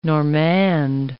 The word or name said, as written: Normande